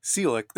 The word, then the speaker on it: Ciolek: C-I-O-L-E-K. And it is Selick. There's no third syllable sandwiched in there. Is that right? – That's correct. Yeah. I guess the best way to describe it is it's similar to Bud Selig, but with the K at the end of it is the correct way to pronounce it, like you did.